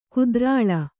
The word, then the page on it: ಕುದ್ರಾಳ: kudra1ḷa